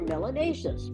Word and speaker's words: melanaceous: If you have more of it, you are melanaceous, which rhymes with gracious and spacious and efficacious.